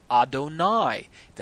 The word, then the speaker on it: Adonai: During the prayer, or when a blessing is recited, or when a Torah verse is read, the four-letter name should be pronounced as if it were spelled Adonai. That's A-D-O-N-A-I.